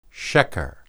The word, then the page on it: sheqer: Hebrew